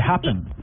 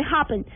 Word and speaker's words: Happn: se llama, se escribe como H-A-P-P-N, como Es como, bueno... – Como de Happen. – sí, como de Happen, pero es mucho mejor que Tinder.